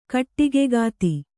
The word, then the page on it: ಕೊಡಗುಕತ್ತಿ: Kannada